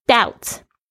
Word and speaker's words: doubt: doubt D-O-U-B-T，千万不要再念那个 but 出来了。 doubt 它的 B-T 那个 B 呢通常都是不发音的，比如说像债务 D-E-B-T， debt 不要念成 debit， OK，那个 B 是不发音的。